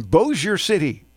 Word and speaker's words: Bossier City: Lex in Bossier City. Is that how you say it? – That is.